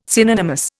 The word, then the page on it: synonymous: Indian